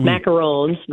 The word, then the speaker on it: macarons: macarons, macarons.